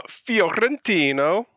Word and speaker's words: Fiorentino: Fiorentino.